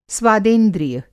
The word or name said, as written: स्वादेंद्रिय